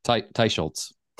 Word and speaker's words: Teicholz: But I think Nina Teicholz, how do you say her last name? Teicholz? – Teicholz. – Teicholz. Thank you.